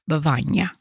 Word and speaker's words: Bevagna: You should really go to Bavagna. So finally, I decided to go. and it has become one of my favorite little towns in Italy. So it's spelled B-E-V-A-G-N-A, Bavagna.